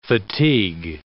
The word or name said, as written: fatigue